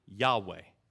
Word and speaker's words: Yahweh: In English, we pronounce it Yahweh.